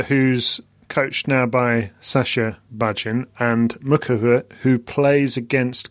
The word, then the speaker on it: Bajin: My understanding is that it's Bajin.